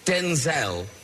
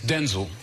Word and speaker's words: Denzel: I was calling you Denzel. Is that correct? – Actually, it's Denzel. – Oh, no, is it really? – Yeah, but... – So was your dad Denzel? – Well, he was Denzel.